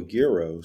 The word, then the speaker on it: gyro: There's a hard G version of it too, a gyro.